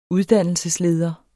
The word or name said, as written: uddannelsesleder